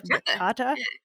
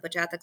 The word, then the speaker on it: bachata: which I think is called bachata or bachata.